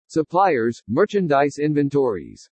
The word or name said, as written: SUPPLIERS-MERCHANDISE INVENTORIES